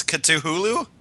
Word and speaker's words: Cthulhu: Okay, so what did we decide on then? It's Cthulhu? – Yes. – All right. I gotta remember that pronunciation.